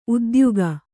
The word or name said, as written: ಉದ್ಯೋಗ